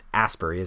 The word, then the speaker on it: Asbury: His first show as a Souls drummer was on Thursday night of last week at Ashbury Lanes in Ashbury Park, New Jersey. It's Asbury, isn't it? I said Ash. – No, Asbury. Asbury.